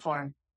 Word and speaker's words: coliform: So a coliform is, and I even have the correct pronunciation that I looked at, and this is how coliform. – That's how you say it.